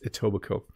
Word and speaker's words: Etobicoke: If you look at it, it's kind of a strange looking name and it looks hard to pronounce, but it's Etobicoke.